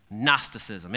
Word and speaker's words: Gnosticism: There was this word, it's kind of a big word, maybe you remember it from some kind of class in school, Gnosticism. Gnosticism. It's got a silent G in the front. You want to say Gnosticism, it's Gnosticism, all right?